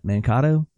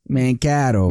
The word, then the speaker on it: Mankato: Mankato? Mankato. – Mankato.